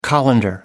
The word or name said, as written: colander